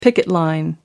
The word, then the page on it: picket line: American English